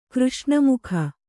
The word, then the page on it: ಕೃಷ್ಣಮುಖ: Kannada